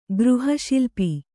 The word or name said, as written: ಗೃಹಶಿಲ್ಪಿ